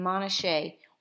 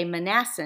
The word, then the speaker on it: Manasseh: And he even mentions Ephraim and Manasseh, or as we used to say, Manasseh, until we met our beautiful friend Yana and learned how to pronounce that correctly.